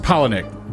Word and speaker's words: Palahniuk: It's actually pronounced Palahniuk, but it's like, okay, well.